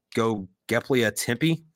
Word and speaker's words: Göbekli Tepe: And then the other thing is, there's this place called, I think it's pronounced Göbekli Tepe. – Yes.